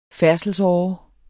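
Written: færdselsåre

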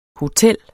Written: hotel

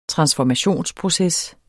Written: transformationsproces

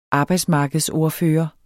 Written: arbejdsmarkedsordfører

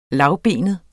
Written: lavbenet

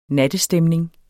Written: nattestemning

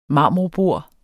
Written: marmorbord

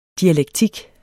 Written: DIALEKTIK

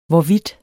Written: hvorvidt

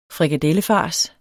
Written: frikadellefars

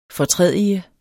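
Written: FORTRÆDIGER

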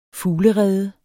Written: FUGLEREDEN

